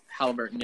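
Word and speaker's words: Haliburton: No, I'd say it's how do you say it? Halliburton, yeah.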